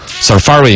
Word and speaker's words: Safari: 他还专门搜了一个这个英语字典那个出来，实际的发音应该是 Safari 应该是这样子读，嘎。 Safari 应该是重音在第二个，好像我之前是有印象的 Safari 浏览器，苹果的浏览器 Safari，英式和美式的发音呢，啊稍微长一点，短一点，英式的要长一点 Safari 和 Safari 听起来差不多了，我要转一点回来，表示我也懂啊。